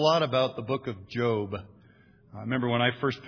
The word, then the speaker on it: Job: He pronounced it Job, so that's what I'm going to do.